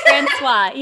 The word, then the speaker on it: Francois: Francois. Yes, it's very French.